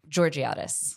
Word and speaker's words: Georgiades: Georgiades.